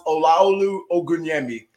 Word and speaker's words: Olaolu Ogunyemi: So my name is pronounced Olaolu Ogunyemi.